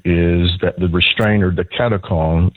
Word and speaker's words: katechon: Katechon.